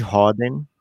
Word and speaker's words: Rhoden: Jared Roden entrou em quadra. Caramba. Roden se escreve R-H-O-D-E-N.